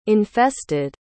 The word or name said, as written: infested